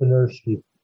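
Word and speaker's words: Benirschke: Rolf Benertz. Okay, I can't even pronounce his last name. – I think it's Benersky. Yes, you're right.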